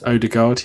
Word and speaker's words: Odegaard: That's Odegaard.